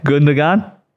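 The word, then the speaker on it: Gündoğan: Now, how do you say it? – Gundogan.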